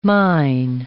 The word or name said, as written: mine